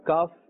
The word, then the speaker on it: kaf: The makhraj of kaf is also part of the lahawiya letters, but it's pronounced from the root of the tongue and the hard palate. Remember, the qaf is pronounced from the soft palate and the kaf from the hard palate.